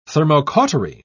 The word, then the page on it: thermocautery: British English